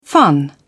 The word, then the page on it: fun: British English